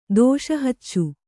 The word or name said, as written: ದೋಷಹಚ್ಚು